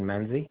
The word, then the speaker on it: Menzi: Wayne Menzie. – Spell that last name correctly. – M-E-N-Z-I. – M-E-N-Z-I.